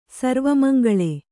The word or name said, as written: ಸರ್ವಮಂಗಲೆ